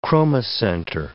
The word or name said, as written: chromocenter